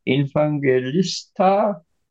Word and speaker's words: Evangelista: dann, dann finde ich halt mit äh, Philipp Tomasino und Luke Infangelista, Evangelista, ist jetzt richtig ausgesprochen, haben sie zwei inter sehr interessante junge Spieler.